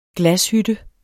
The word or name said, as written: glashytte